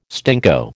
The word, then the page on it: stinko: American English